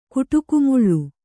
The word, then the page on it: ಕೊಡಗುಮಲೆ: koḍagu male